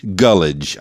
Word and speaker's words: Gulledge: So am I pronouncing your last name properly? – Gulledge.